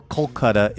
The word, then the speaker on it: Kolkata: Or Kolkata, if you were in India, as I've heard it increasingly pronounced over here. I'm sure it's always been Kolkata in India, but we're just getting hip to that.